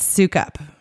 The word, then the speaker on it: Sukup: It's Sukup.